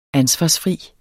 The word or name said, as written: ansvarsfri